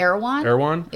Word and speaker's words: Erewhon: And you can get the ingredients at Arrowhorn. How do you say that? – Erewhon. Yeah.